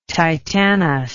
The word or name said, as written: titanous